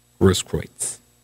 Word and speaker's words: Grosskreutz: That's his name. Grosskreutz. How he pronounces it.